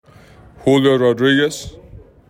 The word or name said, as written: Julio Rodriguez